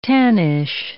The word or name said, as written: tannish